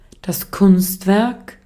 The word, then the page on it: art: /ˈɑːt/; /ˈɑɹt/